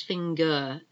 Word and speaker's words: finger: It's like an A, but it's not, it's finger, F-I-N-G-E-R, finger. We don't pronounce the R. Surprise, S-U-R-P-R-I-S-E.